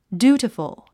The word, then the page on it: dutiful: djúːtifəl; デューティフォ